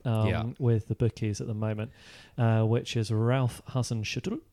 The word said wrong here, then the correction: Hasenhüttl: Yeah, how's my pronunciation? – You just completely bottled it then, didn't you? – Go on, your turn. – Hasenhüttl. – Hasenhüttl.